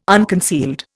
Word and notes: unconcealed: Indian

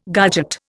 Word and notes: garget: Indian

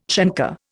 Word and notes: chancre: Indian

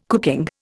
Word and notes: cooking: Indian

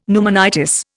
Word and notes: pneumonitis: Indian

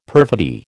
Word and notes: perfidy: American English

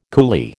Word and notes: coolie: American English

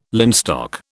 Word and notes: linstock: British English

